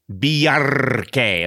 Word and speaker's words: Bjarki: And Bjarke, am I saying it right? – You get full marks.